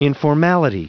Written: informality

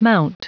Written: mount

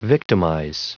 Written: victimize

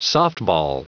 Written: softball

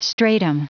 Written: stratum

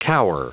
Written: cower

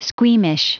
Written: squeamish

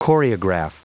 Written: choreograph

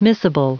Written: missable

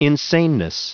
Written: insaneness

